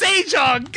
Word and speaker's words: Sejong: Tell Sean it's correctly pronounced Sejong.